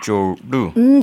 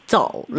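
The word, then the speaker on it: 조루 (走路): 삼성 사성으로 발음은 조루입니다. – 조루. – 잘해주셨어요. 네. 삼성 조금 더 깊게. 조루.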